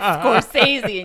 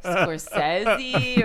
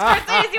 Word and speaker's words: Scorsese: It was because you kept saying, how do you pronounce Scorsese? And everybody was like, it's Scorsese. And you were like, are you sure it's not like Scorsese? Or and everybody was like, no, it's fucking Martin Scorsese.